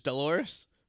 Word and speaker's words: Dolores: I remember the episode of Seinfeld about the name that rhymed with a female body part, which is Dolores. Rhymes with clitoris.